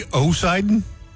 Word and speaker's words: Poseidon: A not silent P that I feel like should be silent. You know what I'm saying? – He would just be Poseidon? – Yeah.